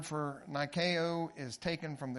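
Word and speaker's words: Nikeo (νίκη): Nike, N-I-K-E. Look it up. It's actually pronounced Nikeo, but in the English it's spelled N-I-K-E.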